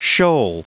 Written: shoal

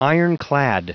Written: ironclad

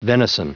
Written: venison

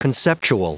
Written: conceptual

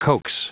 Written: coax

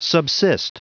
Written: subsist

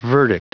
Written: verdict

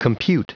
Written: compute

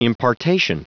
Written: impartation

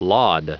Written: laud

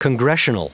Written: congressional

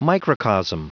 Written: microcosm

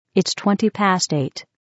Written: 20 past 8

